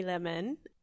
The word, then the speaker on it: Lehmann: Hi, I'm Melanie Lehmann. It's not spelled like my Instagram, actually, but it sounds like it.